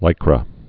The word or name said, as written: Lycra